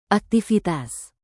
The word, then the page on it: aktivitas: indonesian